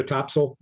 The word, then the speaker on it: Topsail: Hey, have you ever been to Topsail, North Carolina? – I have not. – Well, I've got to do a little research on it. I'm aware that it's pronounced Topsail and not Topsail.